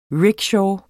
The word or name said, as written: RICKSHAW